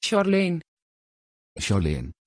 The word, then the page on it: charleen: Dutch